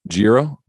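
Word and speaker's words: Jiro: Is definitely in one of the last stories I'll share, and want to obviously ask you a few questions, but, there was Euro Uno. Did I say that right? Make sure I got that. Did I say that? – Giro. – Okay.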